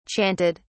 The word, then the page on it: chanted: British English